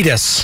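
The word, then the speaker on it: Kiedis: How do you pronounce his last name? Cletus. – Cletus. That's it.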